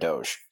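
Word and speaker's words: Doege: And then the big one, in my opinion, former West Virginia quarterback Jarrett Doge. I believe that's how you pronounce it, like the meme.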